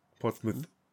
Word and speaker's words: Portsmouth: Portsmouth. Genau so ausgesprochen.